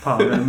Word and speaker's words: Pavel: Ja, och jag vill säga Powell för att han sa Powell med W.